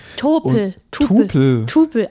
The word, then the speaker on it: Tupel: Tupel? – Tupel, ja. – Das Wie schreibt man das? – T-U-P-E-L.